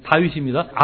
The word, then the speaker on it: 다윗: 우리 사공 집사님이 유창한 영어 발음으로 데이빗하고 말씀하셨는데 우리가 보통 알 수 있는 다윗입니다.